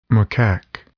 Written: macaque

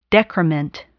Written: decrement

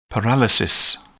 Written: paralysis

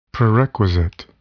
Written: prerequisite

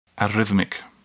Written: arrhythmic

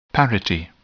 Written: parity

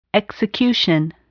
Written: execution

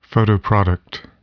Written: photoproduct

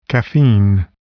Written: caffeine

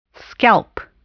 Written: scalp

